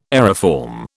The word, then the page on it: aeriform: British English